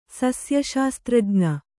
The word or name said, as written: ಸಸ್ಯಶಾಸ್ತ್ರಜ್ಞ